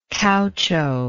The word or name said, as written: caucho